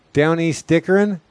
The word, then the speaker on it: Down East Dickering: Down East Dickering? – Mm-hmm. – I don't think I'm saying it right. What now? – That, you said it exactly right.